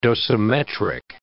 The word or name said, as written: dosimetric